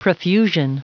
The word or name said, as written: profusion